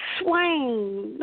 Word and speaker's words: Swain: Samantha, say your last name for me, darling. – Swain, no problem. – I always butcher last name, so I always enjoy the author saying it correctly.